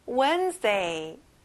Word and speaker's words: Wednesday: 친구들, 여기도 앞에 강세가 나오고요. D 발음은 안 나요. Wednesday.